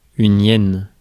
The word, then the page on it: hyène: [jɛn]; /jɛn/, /ʔjɛn/